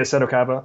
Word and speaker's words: Seto Kaiba: And this comes from Seto Kaiba, is that how you say his name? – Yeah, Seto Kaiba.